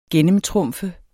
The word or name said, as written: gennemtrumfe